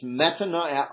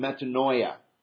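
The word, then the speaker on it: metanoia (μετάνοια): You can't read the Greek, but I can pronounce it. It's metanoia and metanoia.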